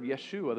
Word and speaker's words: Yeshua: The Hebrew pronunciation and the way it would have been used, it's a Hebrew word, Yeshua, the same as Joshua.